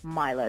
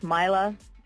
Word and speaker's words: Maila: Because it's spelled M-A-I-L-A? – Correct. – And so people say, I bet they say Malia. – They either say Malia, which is Obama's daughter. – Yeah, how's your dad? – Or they would say Maila. – Okay. – Or they'll pronounce it Mela. – Mm-hmm. – Or if you haven't met me, you might call me Mila at a meeting if you didn't know how to spell my name.